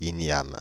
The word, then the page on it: jam: /ʃɛm/; [ʒɛm]